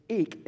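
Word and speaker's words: Eik (εἰκῇ): And the word there he uses that most of the time in English is translated vain, in vain, is eik in the Greek, right? Eik is how you say it.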